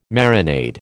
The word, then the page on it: marinade: American English